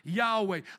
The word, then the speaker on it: Yahweh: If you click on it here in the Hebrew, you see it's Yohevaheh, as we would now pronounce with the vowels, Yahweh.